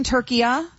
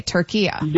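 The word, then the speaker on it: Türkiye: Okay, Turkiya. – There you go. That's perfect. That's how it's pronounced. It's always been pronounced that in Arabic language.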